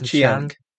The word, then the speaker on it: Chiang: Did I say that right? Is it Chiang? Because it's got an I in it.